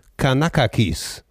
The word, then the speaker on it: Kanakakis: Ah, wie heißt denn der richtig? Wie wird das ausgesprochen? Jorgos Kanakakis. Das ist ein Grieche.